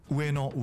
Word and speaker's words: 上野: 你刚刚是不是念成上野？是长野还是上野？ – 乌诶诺乌诶诺，就是上野。